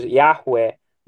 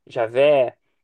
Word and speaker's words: YHWH: Então, olha só, muita gente conhece o nome de Deus como YHWH, né? Que é geralmente entendido como Javé, né? Por muitos, Yahweh, Javé, ou Jeová, né? Assim que é pronunciado.